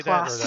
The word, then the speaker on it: Duplass: HBO has canceled the comedy is it the Dunlap brothers, or how do you say Duplass.